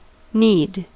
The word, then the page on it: knead: American English